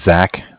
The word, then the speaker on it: Zach: My name's Zach, spelled Z-A-C-H.